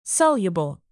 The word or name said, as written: soluble